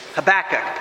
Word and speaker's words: Habakkuk: Probably the most popular would be the way I'm trying to say it, which is Habakkuk, putting the emphasis on the back as you say the name, Habakkuk.